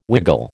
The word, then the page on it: wiggle: American English